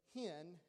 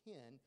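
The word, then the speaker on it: hen (חן): But it's hen, and it looks like C-H-E-N, but it's pronounced hen. And then hesed, and it's also wrote C-H-E-S-E-D.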